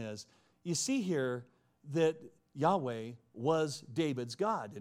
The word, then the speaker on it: Yahweh: And today, most Hebrew scholars believe that God's name should be pronounced Yahweh or Yahweh.